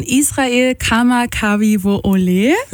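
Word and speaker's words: Israel Kama Kavi Wo Ole (Israel Kamakawiwoʻole): Somewhere Over the Rainbow von Israel Kama Kavi Wo Ole.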